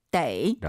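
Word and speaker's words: 得: 동사나 형용사, 즉 수로 뒤에 있으면 떠라고 읽지만 수로 앞쪽에 있으면 데이. – 라고 읽습니다.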